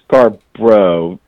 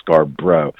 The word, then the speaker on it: Scarbrough: It's Mark Scarbrough, Scarbrough, not like Scarborough Fair, but just two syllables, Scarbrough.